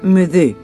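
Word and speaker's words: m'aidez: Según el Museo Nacional Marítimo, My Day surge de la palabra francesa, en español se pronuncia Me Deux, que significa ayúdenme.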